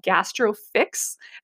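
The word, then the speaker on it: GastroPhix: It's actually going to be called GastroFix, and Fix is spelled P-H-I-X. So it's a play on the pH of the stomach.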